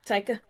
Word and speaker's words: Taika: The next movie is being directed by Taika Waititi, if that's how you pronounce boy. – It. – Taika, yep.